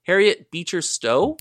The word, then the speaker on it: Harriet Beecher Stowe: One author, I might get their name, her name wrong, Harriet Beecher Stowe.